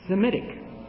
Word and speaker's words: Semitic: The reason they call Shem's offspring Semitic rather than Shemitic is because in the Arabic and the Hebrew, the H is not pronounced. You see? So therefore, it's Semitic.